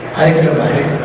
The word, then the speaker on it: Hyderabad: How do you say Hyderabad or Hyderabad? Hyderabad. Hyderabad. Okay.